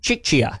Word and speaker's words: Czechia: Czechia. Something like that. – Czechia. – Yeah, Czechia.